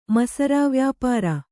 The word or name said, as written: ಮಸರಾವ್ಯಾಪಾರ